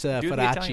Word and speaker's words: Feraci: How do you say it? – Feraci. – You're not going to do the Italian way? – Oh, you want me to do the Feraci?